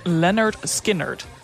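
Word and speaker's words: Lynyrd Skynyrd: Overigens kwam ik er ook net achter dat het album ook precies de titel bevat die hoe je de band moet uitspreken. Er staat ook gewoon pronounced Leonard Skinnerd.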